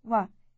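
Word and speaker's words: wa (و): The word for and in Arabic is a single consonant, wa, as in the first sound of the English word wet.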